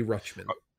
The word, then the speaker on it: Rutschman: Adley Rutschman.